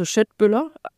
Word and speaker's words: Köttbullar: Also Köttbullar, so wird es ausgesprochen, die Köttbullar.